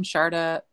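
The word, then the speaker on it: Sjaarda: My last name is spelled S J AARDA, and the S and the J are said like an S and H, but you don't need that for Googling.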